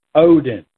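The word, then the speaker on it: Odin (ὠδίν): The word pains there, the pains of death, is the Greek word Odin, long O-D-I-N, and it means birth pangs.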